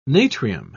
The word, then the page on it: natrium: British English